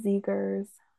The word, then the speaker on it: Zegers: It's Zegers. So that's how you say his last name.